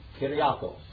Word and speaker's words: Kyriakos: His name is Kyriakos. That's pronounced like a Y.